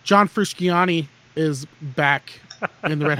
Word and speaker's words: Frusciante: Frusciante. Frusciante. That's how you pronounce it.